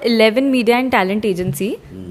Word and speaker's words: Elleven Media: So eleven eleven is the name, but we pronounce it as Eleven Media and Talent.